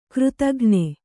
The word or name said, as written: ಕೃತಘ್ನೆ